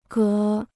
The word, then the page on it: 隔: gé